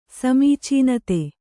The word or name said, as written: ಸಮೀಚೀನತೆ